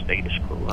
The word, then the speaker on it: Status Quo: Yeah, everyone says Status Quo. No, it's Status Quo. That's the English pronunciation.